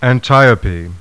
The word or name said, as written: ANTIOPE